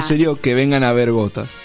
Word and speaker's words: Ghotazz: Gotas, que se llama así, Gotas. ¿Cómo se escribe el grupo? – Se escribe G-H-O-T-A-Z-Z.